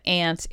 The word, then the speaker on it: aunt: She's absolutely right that that's the way she grew up pronouncing it, and that it is a perfectly acceptable pronunciation. But I think that she might be surprised to know that aunt is the more dominant pronunciation.